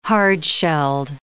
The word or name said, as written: hard-shelled